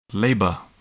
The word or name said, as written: labor